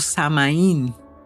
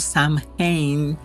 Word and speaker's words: Samhain: denominado Samaín o Samhain, es varias pronunciaciones, que se celebraba el fin de verano y la llegada de los días cortos y fríos de otoño.